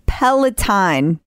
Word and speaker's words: Peloton: It's Peloton. – So we say it right.